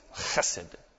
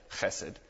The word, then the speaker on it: Chesed: Chesed. That's how you say it. Chesed. It's kind of a fun one to say in Hebrew.